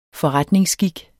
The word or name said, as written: forretningsskik